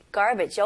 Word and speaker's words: garbage: 중간에 여기 R 있죠? 그래서 R는 혀가 천장을 닿으면 안 돼요. 그리고 혀가 뒤로 당기는 느낌으로 좀 힘을 주셔야 돼요. 그래서 첫 음절은 gar. car 할 때 gar. 그 다음에 두 번째 음절은 bidge. bidge. garbage. garbage. 네, 강세는 첫 음절을 주시면 훨씬 더 원어민스럽게 발음하실 수 있어요.